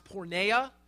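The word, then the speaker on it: porneia (πορνεία): Immorality in the Greek is pronounced porneia.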